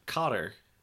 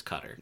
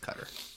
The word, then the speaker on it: Qatar: Cotter. The country? Cotter? – Oh, Qatar? – Qatar? – It's not. You think that's how it's pronounced, it's not. It's Cutter. – It's Cutter.